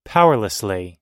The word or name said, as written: powerlessly